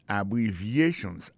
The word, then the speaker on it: abbreviations: Accent tonique qui j'ai pour nous prononcer mot yo n'a pour un mot abbreviations nan qui signifie abréviation abbreviations accent tonique là tombé sous troisième syllabe là